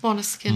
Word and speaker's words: Måneskin: Måneskin.